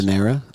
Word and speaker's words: Ganadera: Anyway, production is handled by Agronosa Gandanera. Gandan, is that how you pronounce? – Gandanera. – Gandanera. G-A-N-A-D-E-R-A.